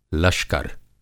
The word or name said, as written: लश्कर